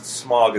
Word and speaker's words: Smaug: They pronounce it Smaug in this movie. The correct pronunciation is Smaug. I have learned.